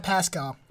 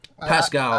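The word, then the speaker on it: Paschall: But moving on, Warriors rookie Eric, is it Pascal? How do you say that? – Pascal. – Pascal. – That's how I've been saying it.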